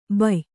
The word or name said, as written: ಭಯ್ಯಾ